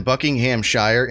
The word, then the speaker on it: Buckinghamshire: It's crazy how, like, over there, they turn Buckinghamshire into just, like, three syllables.